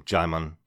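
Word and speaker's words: Djimon: Will B just sent us a message on Twitter to say, just to confirm, Jaimon Hanzo pronounces his name Jaimon just like Simon. The D is silent.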